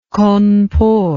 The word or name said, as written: Cawnpore